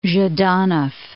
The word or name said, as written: Zhdanov